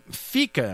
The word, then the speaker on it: fika: Fika, am I saying that right? F-I-K-A? – Yeah, so important for Swedes.